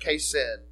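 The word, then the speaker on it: chesed: The Hebrew word is pronounced kesed.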